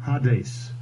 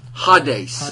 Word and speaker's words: Hades: Hades. – Hades.